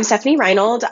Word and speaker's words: Stefanie Reinold: So I'm Stefanie Reinold.